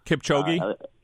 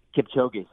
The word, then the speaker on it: Kipchoge: But, Kipchoge? – Kipchoge, yeah, sure.